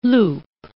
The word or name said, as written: loupe